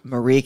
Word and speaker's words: Marie: Like burnt onions and horseradish and a Mary Callender's like meatloaf or something. – Marie Callender's. You put some respect on that name and say it right. It's not Mary. What? It's Marie Callender's.